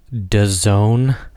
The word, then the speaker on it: DAZN: It's called DAZN, pronounced DAZN.